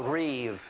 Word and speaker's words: Riv: The scholars call it R-I-B, but the B is pronounced like it's a V. And they call this the Riv. R-I-V is the way it sounds, but it's spelled R-I-B.